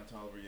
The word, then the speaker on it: Toliver: Don Toliver. Yeah. – Toliver. Yeah. I would never know how to say his last name.